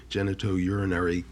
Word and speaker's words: genitourinary: And I just want to go back to something you said, and I hope I pronounce this right, the genitourinary cancers.